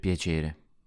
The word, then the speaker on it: piacere: In piacere, the first part is spelt P-I-A, but the I sounds like a Y. So it's pia, not pia.